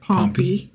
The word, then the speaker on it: Pompey: So in 63 BCE, Roman general Pompey. – Pompey. – It is pronounced Pompey.